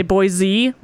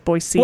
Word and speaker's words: Boise: Oh, that is a point of contention, as many people say Boise with a Z at the end. But anybody who's from here actually says Boise.